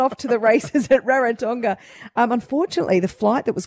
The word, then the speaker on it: Rarotonga: There were a lot of tourists that were off to Rarotonga. Is that how you say it? – Rarotonga. – Yeah.